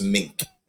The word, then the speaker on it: Meeink: You pronounce my last name, as every man will say, you pronounce it like the animal, and every woman will say, you pronounce it like the coat. It's just Meeink.